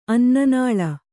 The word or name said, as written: ಅನ್ನನಾಳ